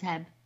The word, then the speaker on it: H-E-B: We don't have Vons here, but we do have H-E-B. – Which I thought was Heb. – You don't call it Heb. It's spelled Heb, but it's H-E-B.